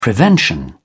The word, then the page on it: prevention: British English